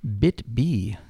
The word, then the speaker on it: BitB: Just when you thought you'd seen every phishing trick out there, BitB comes along. So how does this one work? Is that wrong? Did I say it wrong? – No. That's right, isn't it? – It's just like BITB.